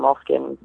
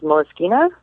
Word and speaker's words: Moleskine: I say it wrong, Moleskine. It's Moleskine.